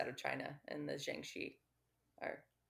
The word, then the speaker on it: Jiangshi: Jiangshi. – Jiangshi. – Yeah. In Chinese folklore. I almost said Jianshi. So I almost butchered the hell out of that word.